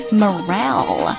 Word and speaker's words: morale: Morale, M-O-R-A-L-E, Morale. 我记得在很早很早之前做这个 QQ 节目的时候，我把这个单词发错音了。那它正确读音叫做 Morale, Morale, M-O-R-A-L-E, Bolster somebody's blah, blah, blah.